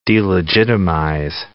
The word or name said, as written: delegitimize